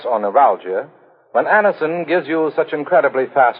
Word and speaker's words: Anacin: It's spelled A-N-A-C-I-N. Anison.